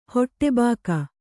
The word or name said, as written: ಹೊಟ್ಟೆಬಾಕಿ